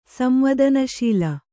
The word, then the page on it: ಸಂವದನಶೀಲ: samvādana śila